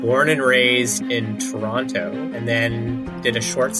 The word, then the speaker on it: Toronto: And you are the only person I've ever known from Toronto to pronounce that second T. – I know. – Almost everyone I know always says Toronto. – I know. – Toronto. – And that's, in fact, the right way to pronounce it, and I get a lot of ribbing for it when I head back there.